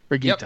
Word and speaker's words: Brigitte: I keep wanting to put a soft G in it, and that's not how it's said. How do we say her name? – I think it's Brigitte. – Brigitte, yep. Okay.